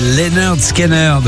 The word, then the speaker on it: Lynyrd Skynyrd: RTL 2 et Lynyrd Skynyrd. Oui, ça se prononce comme ça, je sais, c'est bizarre.